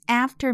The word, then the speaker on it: after: 好，那么这一段他主格，我宾格，he is after me，他在我后面。这一段它押韵的这个。发音呢，就是字母 e 发长音 e 的情况。 He, me。好，下面还有一个注意的发音是 after。注意在美式英语里面呢，a 发的是四号元音，也就是也就是嘴型较大的 a，它的音 after me after。